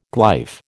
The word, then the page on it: glyph: American English